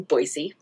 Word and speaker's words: Boise: the inside people in Boise, pronounced it with an S sound, so Boise.